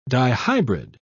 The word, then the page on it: dihybrid: British English